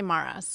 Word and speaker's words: Maras: I just say Maras. – Maras. Okay. – Yeah, but I mean, you're really supposed to roll the R, but I don't.